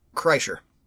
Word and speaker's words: Kreischer: Kreischer.